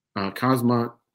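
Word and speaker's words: Khamzat: So he's fighting Shimaev, Hamzat Shimaev, the K is silent, and Stephen Thompson versus Jeff Neal.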